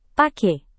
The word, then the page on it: pake: Indonesian